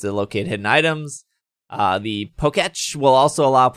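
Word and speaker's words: Pokétch: How do you say it? – Poketch. – Poketch. That's a Poketch. – Like Etch a Sketch.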